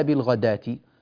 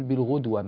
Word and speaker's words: بالغداة: في قوله تعالى بالغداة، كما تلاحظون في رسمها الألف الصغيرة رسمت فوق الواو، ولذلك هذه الواو لا تنطق، لا نقول بالغدوة مثلا، هذه ليست قراءة الإمام نافع، بالغداة والعشي، فكأن الواو غير موجودة البتة، وكأنها ألف تماما.